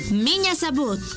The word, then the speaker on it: меня зовут: M-I-Ñ-A. Меня зовут. Z-A-V. U-T. Zabut. Miña Zabut.